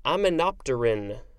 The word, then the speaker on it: aminopterin: Next, we have um, aminopterin. I think that's how it's pronounced. It's spelled like aminopterin, uh, but that's not how it's pronounced. A M I N O P T E R I N.